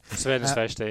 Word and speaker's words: Crvena Zvezda: It was the European Cup exit to Red Star Belgrade, or as you call them quite rightly, a lovely name, Crvena Zvezda. I'm sure I've mispronounced it. – Crvena Zvezda, yeah.